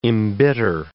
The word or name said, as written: embitter